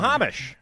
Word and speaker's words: Snohomish: 5 people were from King County and 1 was from, gotten to mispronounce this, Snohomish County. – Snohomish.